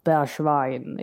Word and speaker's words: Bergwijn: Ehm, och givetvis då det fantastiska avslutet från Steven Bergwijn. Nu uttalar jag det med oerhört eh, engelskt eh, uttal. Eh, jag pratade med någon holländare som, som uttalade det lite mer sådär Bergwijn.